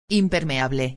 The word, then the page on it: impermeable: espanhol